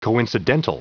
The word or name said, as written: coincidental